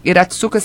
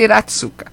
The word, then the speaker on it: Hiratsuka: Iratsuka se escreve assim. Hiratsuka. É isso, é o nome dela, Lúcia Iratsuka.